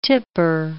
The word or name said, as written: tipper